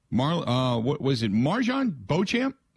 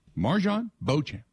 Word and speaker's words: Beauchamp: Marjon Beauchamp is the proper pronunciation? – Yep. – Marjon Beauchamp. Just making sure.